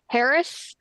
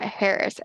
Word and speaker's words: Harrah's: And if you're in Kentucky, they call it Harrah's, which when we got there and checked in, it's not Harrah's, it's Harrah's. – Really? – It's Harrah's up here in the Midwest. – Yeah, that's how they pronounced it there.